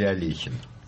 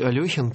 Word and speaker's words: Алехин: Как правильно произносить фамилию? Алехин или Алехин? – Давайте произносить Алехин, потому что есть документы, которые это подтверждают. А вот смертельно борющийся за фамилию Алехин Википедия не пускает туда никого, кто желает это поправить, и распространяет вот такую моду, которая, к сожалению, не права.